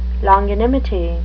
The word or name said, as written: longanimity